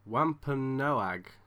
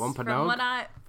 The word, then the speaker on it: Wampanoag: Wampanoag. – Almost. Wampanoag. From what I can gather, it's Wampanoag.